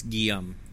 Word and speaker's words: Guillaume: Guillaume is G-I-U-M. Guillaume. – Guillaume. – It's like Liam, but in French.